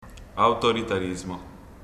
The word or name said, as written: autoritarismo